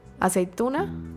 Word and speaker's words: aceituna: But how do you say olive the vegetable? – Aceituna? – Aceituna. That one always throws me off.